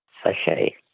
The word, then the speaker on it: sachet: Words that end with a silent T. Which word for a small sealed pouch literally means little sack in French? – Sachet. – A sachet. S-A-C-H-E-T.